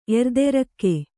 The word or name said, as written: ಎರ್ದೆರಕ್ಕೆ